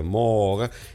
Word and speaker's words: Moog: Bueno, el ingeniero se llamaba Robert Moog, no se pronuncia Moog, se pronuncia Moog, nacido en el año 34 en New York y muerto en el año 2005.